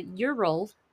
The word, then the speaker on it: Ural: Specifically, we're on the campus of the Ural, which spelled U-R-A-L. – Oh, okay. – Like how you would pronounce the, like, acronym URL.